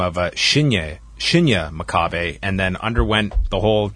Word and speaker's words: Makabe: Tell me about, how do you say, Makabe or Makabe? – Makabe. – Makabe.